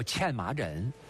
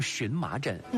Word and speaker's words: 荨麻疹: 这最常见到的一个皮肤病，把它叫欠麻疹。今天大家也把它读成荨麻疹。